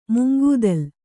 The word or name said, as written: ಮುಂಗೂದಲು